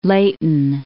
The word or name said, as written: Leyton